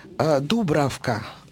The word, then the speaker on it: Дубравка: Мать Дубравка, тут скорее двойное надо ударение, чешская принцесса.